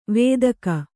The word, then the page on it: ವೇದಕ: Kannada